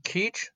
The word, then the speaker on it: Kizh: Uh, their true name is uh, Kizh, spelled by scholars like me K I Z H, but pronounced like teach or reach.